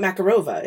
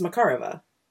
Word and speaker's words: Makarova: It's not Makarova. It's Makarova.